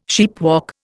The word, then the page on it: sheepwalk: Indian